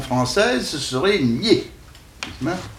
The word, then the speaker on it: Nied: Et puis, il y a aussi deux rivières de Lorraine qui doivent se prononcer nier. – Non, je ne crois pas. – Qui s'écrivent N-I-E-D, mais qui ne sont en pays de langue française. Et les gens du pays disent nier. Mais, alors, ils vous disent même, vous prononcez ça comme pied.